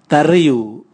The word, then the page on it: tariu: Raga